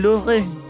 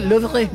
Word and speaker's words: Louvre: Bueno, también un día como hoy, en 1911, se robaron la Mona Lisa del Louvre. ¿Cómo se dice? – Louvre.